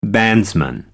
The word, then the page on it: bandsman: American English